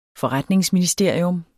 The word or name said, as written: forretningsministerium